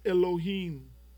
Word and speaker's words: Elohim: The word Elohim, it's pronounced Elohim.